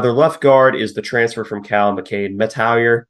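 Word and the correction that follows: Mettauer: My mouth just does not want to form that name. Like, I know how it's pronounced. – Like Metayer? – Metayer, yeah.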